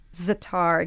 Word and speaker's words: za'atar: I mentioned za'atar. Am I saying that correctly? – Za'atar, yeah.